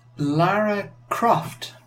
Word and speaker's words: Lara Croft: How do I pronounce Lara Croft correctly in EU? Well, you're from the EU, so. – Lara Croft. – Is that it? – It's not how they said it in the Lara Croft game, but that is how you say it.